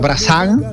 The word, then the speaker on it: Brassens: Se escribe, ajá, Brassens, pero se pronunciaría Brassens.